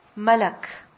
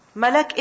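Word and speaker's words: Malak (ملك): The word Malak is originally Mal'ak. Originally the word is what? Mal'ak. But tell me something, is it difficult to pronounce Mal'ak? It is. Why not simply say Malak? Mal'ak, Malak. What's easier? Malak.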